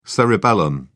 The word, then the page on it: cerebellum: British English